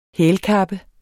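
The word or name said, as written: hælkappe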